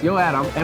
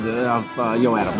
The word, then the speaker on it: YooATOM: That's how it's pronounced, YooATOM. And we got Cho here, and you're the founder of YooATOM.